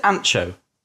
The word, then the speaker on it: Anxo: Um, how do I pronounce? Is it Anxo? yeah. It yeah. Yep. Yeah. I've, Anc- Anco.